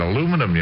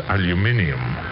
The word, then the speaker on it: aluminium: And I said aluminum yesterday, and she corrected me, I mean, very properly. She says, you're pronouncing it wrong. It's aluminium.